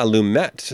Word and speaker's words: Lumet: Lumet. He does pronounce the T. – Ah, Lumet.